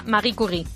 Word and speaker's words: Marie Curie: A ver, sería Marie Curie.